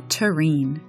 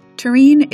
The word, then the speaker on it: terrene: The word is terrain. That's spelled T-E-R-R-E-N-E. Terrain is an adjective that means earthly, worldly.